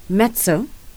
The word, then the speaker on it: mezzo: It's the Fuse 984 myself, and I'm joined in studio with J'Nai Bridges, who is a Grammy Award winning mezzo.